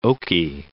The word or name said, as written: Oki Archipelago